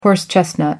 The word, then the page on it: horse chestnut: American English